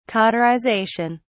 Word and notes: cauterization: British English

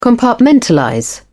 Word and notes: compartmentalize: British English

